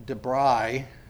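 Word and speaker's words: Divrei: What looks like Dibrei would probably be pronounced with a V or an F.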